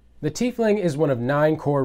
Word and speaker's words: tiefling: Christopher Perkins from D&D has also confirmed that the pronunciation of the word is tiefling.